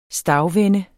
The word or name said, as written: stagvende